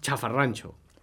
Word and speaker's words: xafarranxo: Chafarrancho. – Chafarrancho. – I pronunciar així, tal com ho pronunciem aquí, s'escriu amb X, d'acord?